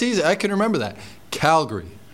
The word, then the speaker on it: Calgary: So it's not Calgary, it's not Calgary, it's Calgary. So think of it as a G-R-E-E at the end.